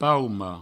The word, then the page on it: pauma: paumo; provencal